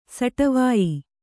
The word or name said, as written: ಸಟವಾಯಿ